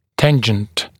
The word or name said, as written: tangent